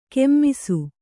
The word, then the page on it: ಕೆಮ್ಮಿಸು: kemmisu